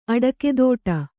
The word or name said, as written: ಅಡಕೆದೋಟ